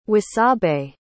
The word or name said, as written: wasabe